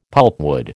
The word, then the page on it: pulpwood: American English